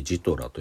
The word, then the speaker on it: GTRA: ジトラと読みます